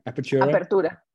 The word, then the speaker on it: Apertura: Tigres played tonight, Friday night, in the semi-final of Legia Apoeta. Am I saying that right? Apertura. Man, every time.